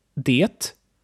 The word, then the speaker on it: det: We had det, which means it, and it's mostly pronounced like de.